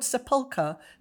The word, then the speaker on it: sepulchre: It has a slightly, I want to say the word sepulchre. Is that how you pronounce it? Yes, it's quite ghostly.